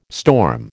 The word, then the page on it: storm: American English